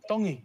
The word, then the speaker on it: Tongi: My name is Iam Tongi, and I'm from Kahuku, Hawaii. – Iam Tongi. – Tongi. You pronounce the T's like Tongi. A T in the Tongan culture. Tong. Yeah.